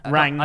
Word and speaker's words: Rangnick: How do you say his name? – Rangnick.